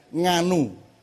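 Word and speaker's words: Ngannou: Juga sekarang ada pemain MMA, juara kelas berat UFC namanya, orang dari Kamerun namanya Nganu. Nek wong Jawa kan gampang nganu Mas, sama ngaeng itu kan kalau di awal kan nganu, ngopo, nguno, ngunu gitu ya. Itu namanya N-G-A-N-O-U. Orang barat semua membacanya N-G-A-N-U.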